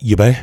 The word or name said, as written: Yibe